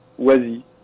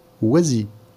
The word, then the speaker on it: Oisy: s'appelle Oisy. – Oisy.